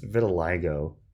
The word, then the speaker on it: vitiligo: How do you even pronounce this word? It's like vitiligo. Is that how you say it? Let me research how to pronounce this word in 1 second. Okay, so it's vitiligo.